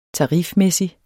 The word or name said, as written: tarifmæssig